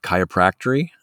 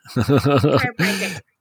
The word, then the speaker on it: chiropractic: But I just want to jump in here and say, you know, what about people that will use chiropractor, how would you say it? Chiropractory? – Chiropractic. – Yes. So use that for, and you cut off, by the way, say it one more time.